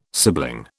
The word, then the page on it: sibling: British English